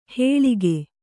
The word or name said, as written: ಹೇಳಿಗೆ